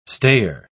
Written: stayer

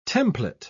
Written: template

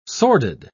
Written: sordid

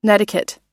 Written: netiquette

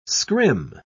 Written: scrim

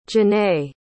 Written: jennay

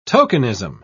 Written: tokenism

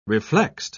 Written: reflexed

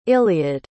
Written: iliad

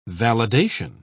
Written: validation

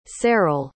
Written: Ceryl

